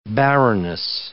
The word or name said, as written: baroness